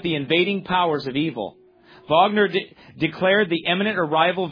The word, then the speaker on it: Wagner: Oh, that's how they say it? Oh, they say it with a V. Sorry. Okay. Wagner.